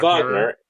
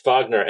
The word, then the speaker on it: Wagner: Is it like Richard Wagner? – Yeah, it's Wagner. It's Wagner.